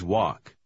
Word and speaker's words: walk: Помните, что буква «Л» в последнем слове немая. – We always walk.